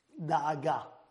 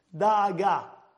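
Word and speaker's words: Da'aga (דאגה): How do you say the word in Hebrew? Worry. Da'aga. Da'aga. Dalit, Aleph, Gimel, Hey.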